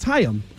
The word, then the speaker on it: Chaim: I don't think you say his name right, by the way. – Chaim. – It's Chaim.